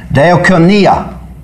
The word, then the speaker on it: Diakonia: Diakonia. This is a double E.